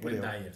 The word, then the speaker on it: Wayne Dyer: Y este libro que se llama Tus Zonas Erróneas, de Wayne Dyer, lo pronuncié bien. – Wayne Dyer, sí.